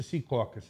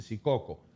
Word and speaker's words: Secaucus: Secaucus es como se dice. No se dice Secaucus, se dice Secaucus.